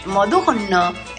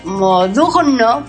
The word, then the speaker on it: Modugno: Es que Modugno es el igual que Perpignan, es ñe, la G. – Sí, la G, N-G. – Se pronuncia ñe.